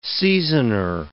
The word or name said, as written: seasoner